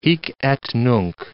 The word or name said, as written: hic et nunc